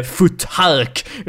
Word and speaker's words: futhark: det står F-U-T-H-A-R-K när man eh, döper de här gamla unalfabeten. Men det uttalas inte futhark, utan det är ju ett ljud så det uttalas futhark.